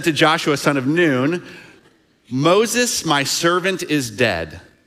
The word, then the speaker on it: Nun: son of Nun. Now, actually, that's pronounced Noon.